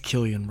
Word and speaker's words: Cillian: You were also wrong about Cillian Murphy. – It's Killian, Brian. – I said that. Shut up. – It's Killian.